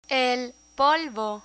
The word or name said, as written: polvo